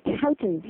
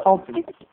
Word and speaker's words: toutons: It's spelled T-O-U-T-O-N-S. – Oh, Tautens, yes. – Yes, Tautens.